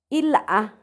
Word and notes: ilhaa: Macuiltianguis

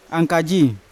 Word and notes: ankaji: Yawa

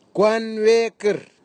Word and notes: kwanwekir: Nafe; Tanna

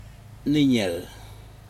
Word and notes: niNec: Aneityum

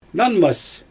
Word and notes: nanmas: Xyzyl

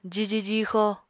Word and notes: jiji jixo: apatani

